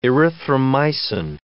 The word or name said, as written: erythromycin